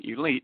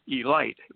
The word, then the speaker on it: Elite: A lot of people like to pronounce the name Elite, but it's Elite.